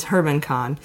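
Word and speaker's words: German Khan: He's the son-in-law of a Russian oligarch named Herman Kahn, which is spelled like German Kahn. But it's Herman Kahn, who runs Alpha Bank, or he doesn't run it.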